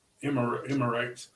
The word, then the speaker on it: Emirates: I thought it was in Emirates, or whatever it's called. If I said that right. – Emirates.